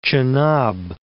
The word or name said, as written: Chenab